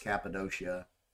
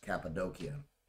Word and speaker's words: Cappadocia: In the region of Turkey, known as, someone pronounced it Cappadocia, but I think it's actually supposed to be pronounced Cappadocia.